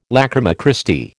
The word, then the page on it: lachryma Christi: American English